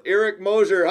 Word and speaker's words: Eric Moser: Some of the guys here in Leicester, they go Moser. – Moser instead of Mosier, and yeah.